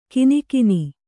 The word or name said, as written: ಕಿನಿಕಿನಿ